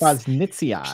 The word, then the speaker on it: prausnitzii: prausnitzii, am I pronouncing that correctly? – Prausnitzii, yeah. – It's prausnitzii. – P-R-A-U-S-N-I-T-Z-I-I.